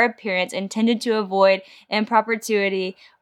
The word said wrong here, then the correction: impropriety: or indecency. – Impropriety. – Impropriety. Thank you.